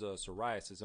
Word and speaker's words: psoriasis: and the subject was psoriasis.